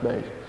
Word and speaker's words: Bass: Okay, for those of you that came in late, Mimi Bass, who's part of our congregation here, spelled like Bose, but it's pronounced Bass.